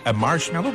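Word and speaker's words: Marshmello: La preuve que vous êtes au meilleur de la musique, c'est Marshmallow, comme ça qu'on le prononce. – Ouais, tu l'as bien.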